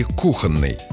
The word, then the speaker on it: кухонный: Правильный вариант один. Кухонный с ударением на первый слог. Объясняется это просто. Слово «кухонный» образовано от «кухня», и ударение важно сохранить, поэтому оно не переезжает дальше и остается на первом слоге.